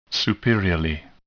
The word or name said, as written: superiorly